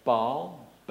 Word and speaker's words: Baal: After the tremendous defeat of the prophets of Baal on Mount Carmel, God, by the way, there's a great debate about whether you should say Baal, whatever. There are two vowels in it, and so you pronounce them both separately, okay?